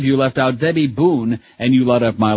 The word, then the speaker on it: Boone: Debbie Boone rhymes with spoon and moon and June and coon, and we hate Debbie Boone.